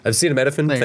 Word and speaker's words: acetaminophen: I know this is on the outskirts of your research here, but they gave subjects, I don't know how to say this word, acetaminophen. It's like what's in Tylenol. We've all seen that word a million times. – Acetaminophen? – Acetaminophen, thank you.